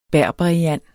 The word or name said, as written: berberiand